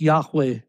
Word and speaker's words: Yahweh: The four Hebrew consonants, as I said, are Yud, He, Vav, He, and together they're pronounced like Yahweh. There's a breathy sound to it.